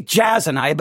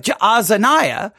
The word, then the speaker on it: Jaazaniah: Yeah, everyone knows the problem in verse three, obviously, because it says, then I took uh, Jaazaniah. I always want to say Jazaniah, but Jaazaniah, the son of Jeremiah.